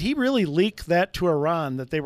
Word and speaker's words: Iran: By the way, I got to ask, I've always said Iran. Am I saying it wrong or am I saying it right? – That's perfect pronunciation, yes.